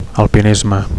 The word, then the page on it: alpinisme: Catalan